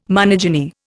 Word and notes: monogyny: Indian

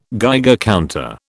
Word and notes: Geiger counter: British English

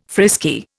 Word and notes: frisky: Indian

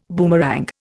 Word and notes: boomerang: Indian

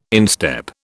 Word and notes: instep: British English

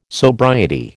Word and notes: sobriety: American English

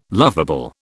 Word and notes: loveable: British English

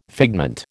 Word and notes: figment: American English

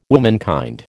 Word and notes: womankind: American English

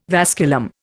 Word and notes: vasculum: Indian

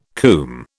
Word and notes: cwm: British English

